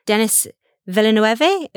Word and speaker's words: Denis Villeneuve: And also the director, Denis Villeneuve. I'm assuming that I'm saying that right. I might be saying it wrong. – I think you said that perfectly.